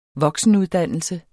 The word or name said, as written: voksenuddannelse